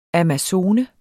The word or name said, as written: amazone